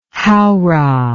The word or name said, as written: howrah